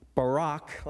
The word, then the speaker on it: Barak: But when you do a little lexical study of the word Barak, like Barack Obama, that's how it's pronounced in Hebrew, it literally means this: to praise, to speak well of, to articulate someone or something with gratitude and affection.